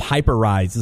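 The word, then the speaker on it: Hyperice: They're teaming up with, I guess it's called Hyper Rise is how it's pronounced. – Yeah. – It's spelled Hyper Rise. Hyper Ice.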